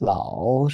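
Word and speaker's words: lao (老师): so that we'll pronounce the first character there like lao with a rise, and that lao shi, and then they'll say no, it's lao, and they'll say it again with the rise, and then you'll It was bizarre the number of the conversations I had like that.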